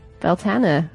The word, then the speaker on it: Beltane: Beltana, apparently, is how it's pronounced.